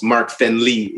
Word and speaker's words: Fenly: Et c'est F-E-N-L-Y, you know. Des fois, le monde pense que je dis friendly, mais c'est friendly.